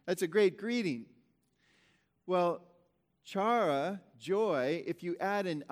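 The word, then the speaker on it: chara (χαρά): And actually, if you go to, you can pronounce it chara or chara. I believe in modern Greek it's pronounced chara.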